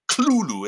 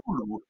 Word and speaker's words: Cthulhu: And one of the stories he wrote was, now I pronounce it Cthulhu. It's hard to pronounce because Lovecraft gives differing pronunciations in various letters, but I think it's Cthulhu, but no matter, who cares? It doesn't matter. You know, it was not meant to be pronounced by human vocal cords anyway, as Lovecraft says, right. – So you can pronounce it any way you want.